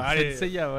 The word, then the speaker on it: Saint Seiya: T'as mal prononcé, c'est pas Saint Seiya, c'est Saint Seiya. – Saint Seiya, ouais.